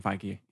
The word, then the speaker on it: Feige: Is that how you say it? Feige? – Kevin Feige, yeah.